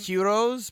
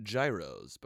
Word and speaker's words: gyros: Heroes, spelled gyros, but pronounced heroes. There you go. There it is. – That's what it is. I call them gyros.